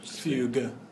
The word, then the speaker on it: fugue: Fugue. – Fugue. – Hard G, fugue. Not soft.